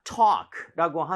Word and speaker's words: talk: 자, talk라고 하는 것. L 발음은 당연히 모음이죠, 그렇죠? 오케이, 그래서 발음할 때 근데 talk라고 하지 말아주시고요. – 네. – A의 강세를 주시고 그리고 입 모양을 좀 벌려서 talk보다 오히려 talk 이렇게 talk. 어에 가깝습니다. 차라리.